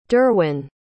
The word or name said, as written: durwyn